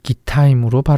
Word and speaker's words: 기타임: 부에롯은 히브리어로 두 개의 우물이라는 뜻이고 기타임은 히브리어로 기타임으로 발음되는데 두 개의 포도주 틀이라는 뜻입니다.